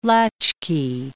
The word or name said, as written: latchkey